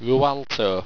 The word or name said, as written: wrualto